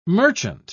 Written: merchant

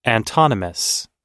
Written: antonymous

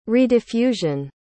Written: rediffusion

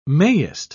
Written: mayest

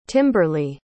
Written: Timberly

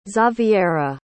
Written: xaviera

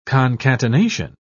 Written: concatenation

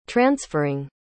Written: transferring

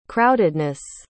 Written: crowdedness

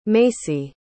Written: macey

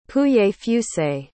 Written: pouilly-fuissé

